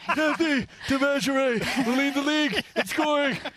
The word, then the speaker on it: Devashrayee: It's Devashrayee. Are we saying that correctly? – Yeah, that's correct. – Devashrayee.